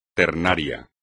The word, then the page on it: ternario: espagnol